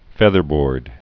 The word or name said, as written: featherboard